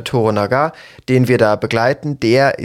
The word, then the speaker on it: Toranaga: Wir begleiten aber diesen Lord Toranaga, genau. So ist es ausgesprochen, richtig.